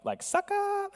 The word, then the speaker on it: Sychar: It's like Sychar, that's how you pronounce it. It's S-Y-C-H-A-R.